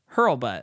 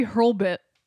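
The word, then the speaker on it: Hurlbut: It's H-U-R-L-B-U-T. Hurlbutt. – Maybe Hurlbit. – Hurl, but it's a U. Hurlbutt.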